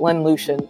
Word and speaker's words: Lynn Lucien: I like to say my name in Creole, but it's really, as you said it, Lynn Lucien in English.